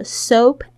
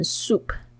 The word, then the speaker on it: soap: With soap and water, 这个 soap and water 就是肥皂跟水的意思。 Soap, 大家特别注意一下，有很多这个学生会念成 soup, soap and soup, 两个不同的东西哦。 Soap, S-O-A-P, 你要注意这个 O-A 的发音是发 O, 这个双元音 O, soap, soup, S-O-U-P 是汤的意思，所以 soap 跟 soup 的发音千万不要搞混了。